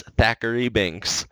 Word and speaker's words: Thackeray Binks: One thing I wanted to point out before we get too far is in the intro here, Thackeray Binks. It's spelled T-H. It's not a Z. Mm-hmm. I watched the credits to make sure. It's Thackeray Binks.